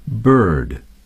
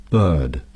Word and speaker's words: bird: 所以这一点呢，我想 会为大家读一下 bird 和 hurt 这两个单词，因为它能更好地展示这两个单词的在美式英语的读音是怎么样。 – bird bird hurt hurt Some speakers of British English do not sound the R in this word.